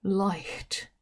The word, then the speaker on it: light: なぜかというと、 light。 G と H は、ちゃんと息として発音しているのです。